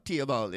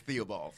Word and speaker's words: Theobald: Well, I'm West Indian folk. They don't pronounce the H, so it is. Okay. In Trinidad, it's Mark Theobald. – Mark Theobald. – But I'm in Brooklyn, so it's Theobald.